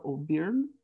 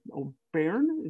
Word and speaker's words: O'Byrne: I'm super excited here to have an absolute subject matter expert, globally recognized, Mr. Rob O'Byrne. O'Byrne, is that how I'm pronouncing that? – O'Byrne, it's this weird O'Byrne.